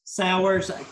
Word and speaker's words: Sauers: I'm your host, and I am really excited to be here with Cody Sauers.